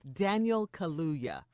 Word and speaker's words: Daniel Kaluuya: And I struggle pronouncing his name, but it's Daniel Kaluuya.